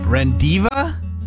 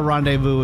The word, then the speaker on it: rendezvous: R-E-N-D-E-Z-V-O-U-S. R-E-N-D-E-Z-V-O-U-S. – Rendiva?